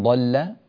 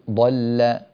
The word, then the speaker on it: ضل: إن ربك هو أعلم بمن ضل عن سبيله. – رقق اللام ضل. – إن ربك هو أعلم بمن ضل عن سبيله. – ما زالت يعني انطق الكلمة وحدها، قل ضل.